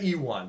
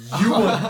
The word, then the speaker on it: Ewan: I've always said Ewan, but it's Ewan.